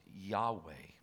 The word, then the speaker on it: Yahweh: That is the root of a Hebrew pronounced Yahweh.